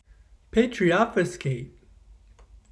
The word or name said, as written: patriobfuscate